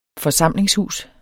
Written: forsamlingshus

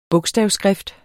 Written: bogstavskrift

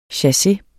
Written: chasse

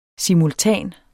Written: SIMULTANE

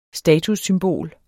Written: STATUSSYMBOLER

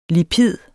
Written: lipid

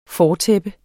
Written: fortæppe

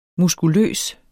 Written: muskuløs

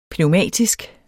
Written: pneumatisk

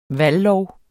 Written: valglov

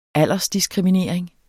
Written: aldersdiskriminering

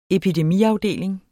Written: epidemiafdeling

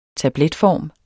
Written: tabletform

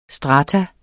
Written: STRATUMS

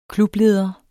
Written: klubleder

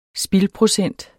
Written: spildprocent

